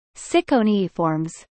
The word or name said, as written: Ciconiiformes